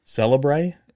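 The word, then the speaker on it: célèbre: So the word is spelled C-E with a line going one way, then L-E with a line going the other way, and then B-R-E. Celebre is what I would say.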